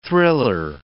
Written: thriller

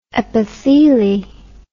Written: epitheli

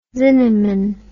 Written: zinnemann

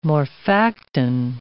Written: morphactin

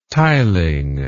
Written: tiling

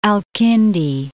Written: Kindi, al-

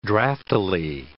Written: draftily

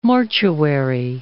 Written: mortuary

